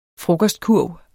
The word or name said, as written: frokostkurv